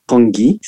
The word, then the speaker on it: congee: I think we pronounce it congi, C-O-N-G-E.